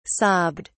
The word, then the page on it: sobbed: British English